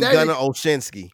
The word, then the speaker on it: Olszewski: You can Devin Asiasi and Gunnar Olszewski. – Olszewski, there. The way that it's spelled, you would never think that that's how it's pronounced.